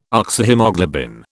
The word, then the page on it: Oxyhaemoglobin: British English